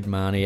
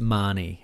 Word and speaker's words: Mahoney: Reed Mahoney out for season. – Reed Marnie. As our dear friend Ken O'Currawong would know, it's Marnie, as the Irish would pronounce it.